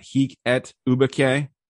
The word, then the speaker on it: hic et ubique: And then you have we looked up how to pronounce this before, but I already forgot. Hic et ubique. – I think you nailed it.